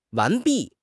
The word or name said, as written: 完毕